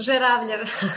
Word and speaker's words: Zeravljev: Yeah, it's close. Zeravljev.